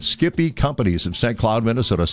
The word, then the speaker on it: Skippy: I did not know what Skippy was all about, so I, of course, mispronounce it as Sippy.